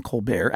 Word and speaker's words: Colbert: When he moved to Chicago, he took the French pronunciation of his name, Colbert, at his father's, if not urging, then at least advanced permission.